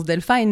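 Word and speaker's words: Delphyne: Bah, en anglais, ça se prononce Delphine, mon nom, si on le lit comme ça.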